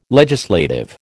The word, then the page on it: legislative: American English